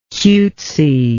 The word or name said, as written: cutesy